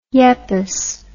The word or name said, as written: Yepes